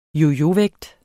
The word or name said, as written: yoyovægt